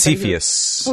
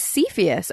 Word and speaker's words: Cepheus: named Cepheus. And am I pronouncing that right? – It's Cepheus. – Oh, it's Cepheus. Okay, cool. – Cepheus.